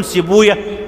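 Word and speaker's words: سيبويه: وقال حاشد ابن إسماعيل رأيت إسحاق بن راهوية جالسا على المنبر، وطبعا احنا بنقول راهوية ما بنقولش راهوي، بنقول سيبوية مش سيبوي، لأن هناك نطقان صحيحان، المحدثون ينطقون كل اسم ختم بوي كما أنطقوا هكذا أوية، لأنهم يرون حديثا ضعيفا أن ويه اسم شيطان فبيشيلوا دماغهم، يعني الحديث ضعيف بإجماع المحدثين أن ويه اسم شيطان، ولهذا هم يفرون فيقولون سيبوية حمدوية نفطوية وهكذا.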